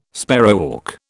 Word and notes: sparrowhawk: British English

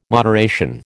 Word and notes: moderation: American English

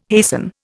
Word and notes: hasten: Indian

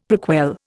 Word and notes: prequel: Indian

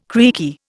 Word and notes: creaky: Indian

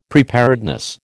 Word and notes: preparedness: American English

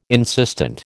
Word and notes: insistent: American English

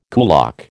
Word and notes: kulak: American English

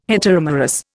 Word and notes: heteromerous: Indian

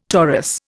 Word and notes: torus: Indian

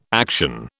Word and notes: action: British English

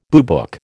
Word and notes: boobook: American English

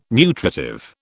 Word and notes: nutritive: British English

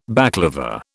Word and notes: baclava: British English

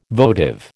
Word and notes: votive: American English

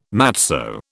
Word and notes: matzo: British English

